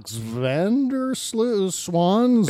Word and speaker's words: Van der Zwan: The scope of this, and that's why indictments like Alex van der Zwan. – Van der Zwan actually would be the proper pronunciation because they're V's or W's or V's, but it's just say Zwan. It's Swan in Dutch.